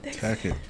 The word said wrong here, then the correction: tacit: Whoa! – It's tacit. – Tacit? Is that tacit? – Is that how you say it? – Tacit. – T-A-C-I-T.